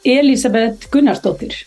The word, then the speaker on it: Elisabet Gunnarsdottir: I'm going to ask you to pronounce your name because I probably gave it a very Swedish pronunciation. So, Elisabet Gunnarsdottir.